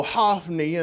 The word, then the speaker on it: Hophni: And thy two sons also, Hophni, and that's the way Hebrew pronounces it, and Phinehas are dead.